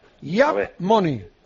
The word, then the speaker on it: Yaap Money: Yap Money. Se escribe YAAP Money, como suena. – Yo la tengo. – Yap Money.